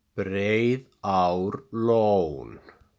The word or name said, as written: Breiðárlón